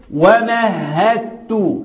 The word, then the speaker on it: ومهدت: اقول ومهدت هدت الدال ما تتنطقش. ومهدت له تمهيدا ومهدت ومهدت الدال لا تنطق.